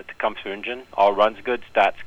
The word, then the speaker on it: Tecumseh: All right, so Tecumseh. That's how you pronounce that, Tecumseh.